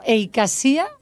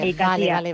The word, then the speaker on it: Eikasia: Y no sé si lo he dicho bien, por cierto, Eikasia. Buenos días, Desirée. – Hola, muy buenos días. Sí, lo has dicho perfectamente. – Lo he dicho bien. Eikasia.